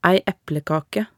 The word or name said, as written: ei eplekake